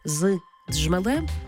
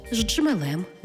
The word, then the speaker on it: з джмелем: Тобто кажемо не «з джмелем», а «ждджмелем». Не «з джерела», а «з джерела».